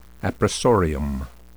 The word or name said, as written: appressorium